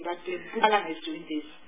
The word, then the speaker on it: Mpumalanga: he pronounces Mpumalanga correctly, because that is the word we say. Not Mpumalanga, it's Mpumalanga. That's my problem.